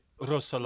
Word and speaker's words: Rousseaux: How easy was that to do then, early in your career, when you signed for, and I'm so sorry, is it Rousseaux, you can pronounce it with your R.